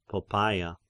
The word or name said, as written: Poppaea